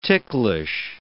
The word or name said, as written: ticklish